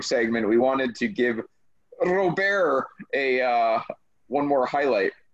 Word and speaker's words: Robert: Robert one day. Lift off. They just have to pronounce their T's.